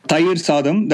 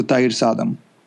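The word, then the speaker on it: Thayir Saadam: Thair Sadam,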